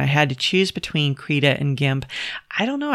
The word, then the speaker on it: Krita: Now, I always pronounced it Krita, but I recently found that it's supposed to be called Krita. It's K-R-I-T-A.